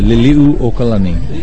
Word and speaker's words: Liliuokalani: I used to live on Oahu during the Onipa'a, the 100th anniversary of the overthrow of the government under Queen Liliuokalani. Did I say that right? – You said it pretty good. Liliuokalani.